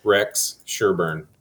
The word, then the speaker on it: Rex Sherburn: I'm Rex Sherburn.